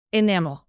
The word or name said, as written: enamel